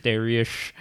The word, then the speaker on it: Dariush: It's spelled with an H at the end. So it's not Dariush. Dariush.